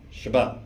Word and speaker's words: Shabbat: That word in Hebrew is the word Shabbat. – その休まれたがサバス、ヘブル語で。 – It's the word that we get the Sabbath from, Shabbat. – そのサバっていうちょっと発音少し違いますが、英語でサバスって言ってるその言葉はこのシャバっていう言葉から取りました。